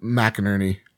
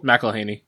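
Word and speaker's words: McElhenney: Rob McInerney. Cool. – McElhaney.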